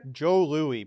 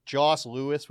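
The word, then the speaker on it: Jos Louis: Joe Louis, but it's spelled J-O-S and then Louis with an S. Like Joss Louis would be a joke we would say when we were kids.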